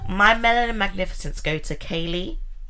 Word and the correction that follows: Kayla: So I'm pronouncing her name wrong. Sorry. Kayla E. Willis.